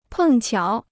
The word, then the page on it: 碰巧: pèng qiǎo